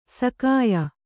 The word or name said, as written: ಶಾಕ್ಯ